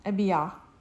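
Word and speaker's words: Abijah: It's not Ha, it's Yah. – Abiha.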